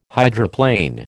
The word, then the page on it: hydroplane: American English